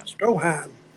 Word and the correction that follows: Strowman: What? How do you say his name? – Strowman.